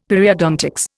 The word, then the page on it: Periodontics: Indian